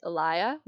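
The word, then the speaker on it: Elaia: So it's pronounced Elia Wellness, and that Elia actually means olive tree.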